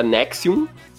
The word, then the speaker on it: NXIVM: Chamada de Nexium, mas se escreve N-X-I-V-M. Se pronuncia Nexium,